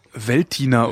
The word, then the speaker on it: Veltliner: Man sagt wohl Veltliner oder so ähnlich mit einem nicht ganz gesprochenen L beim Liner, aber so richtig.